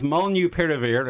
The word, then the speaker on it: Molnupiravir: It's pronounced Molnupiravir. It's a horrible mouthful.